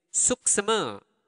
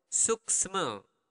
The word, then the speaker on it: suksma: It's suksema. Okay? Slower. Suksma. The natural speed, suksma. Suksma. Suksma. Okay, suksma. Suksma. Suksma. That would be the accent, my accent.